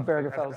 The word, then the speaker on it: Obergefell: Obergefell, right? – It's tough. – Obergefell. – Hard G, I think.